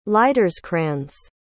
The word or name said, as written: liederkranz